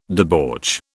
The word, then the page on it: debauch: British English